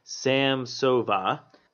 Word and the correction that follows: Samsonova: Samsonova. – Samsonova.